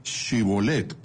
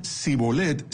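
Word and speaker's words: Shibolet (שיבולת): De ahí que, en lugar de decir Shibolet con la H, pronunciando las consonantes SH algo más suave que una H, dijeran Sibolet sin la H intermedia, y de ese modo se descubrían.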